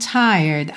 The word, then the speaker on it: tired: Seguindo, making love with you has left me peaceful, warm and tired. Aqui ele juntou warm and tired. Tired, atenção que você não pronuncia a letra E, ok? E esse D pode aparecer mais, menos ou nada.